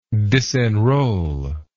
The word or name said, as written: disenroll